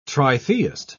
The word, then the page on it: tritheist: British English